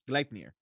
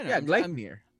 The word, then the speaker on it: Gleipnir: Gleipnir. – Okay. – What? It's easy. Gleipnir.